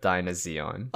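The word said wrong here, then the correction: Dynazenon: And sorry, Dinozanon.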